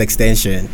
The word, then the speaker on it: XXXTentacion: If you pronounce that right, it's Extension. – Extension? – Yeah. Or like if you really want to read it the way it's spelled, whatever.